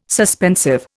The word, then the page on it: suspensive: Indian